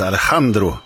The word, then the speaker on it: Alejandro: även om det uttalas på det spanska sättet Alejandro, som ju är både nyare och mer populär kanske än Alexanders Ragtime Band.